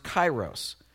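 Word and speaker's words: kairos (καιρός): It's pronounced kairos.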